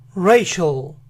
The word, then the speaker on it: racial: El inicio de esta palabra es rey, rey, rey. Entonces es racial, racial, racial.